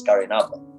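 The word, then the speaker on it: Garrynabba: The interesting thing is, though, that the people here still pronounce it as Gari an Abba in Irish. They say it in Irish, and they say Lugachampel in Irish still. They don't say Lugatemple, which is how it's spelled.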